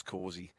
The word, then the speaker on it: Causey: Two syllables, Causey. Exactly right.